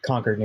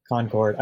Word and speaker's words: Concord: I would be remiss if I didn't say it is pronounced Concord, New Hampshire. – What did I say? Concord? – Concord.